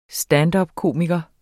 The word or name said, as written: standupkomiker